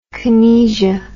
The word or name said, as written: kinesia